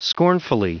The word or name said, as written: scornfully